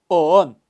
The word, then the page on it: oon: Kaqchikel